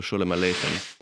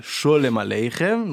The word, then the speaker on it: Sholem Aleichem: Donc là, ce qu'on vient d'écouter, c'était Shalom Aleichem. non, non pas Sholem Aleichem. Donc il y a la chanson et il y a l'écrivain. Il ne faut pas confondre les deux. Ça ne se prononce pas pareil.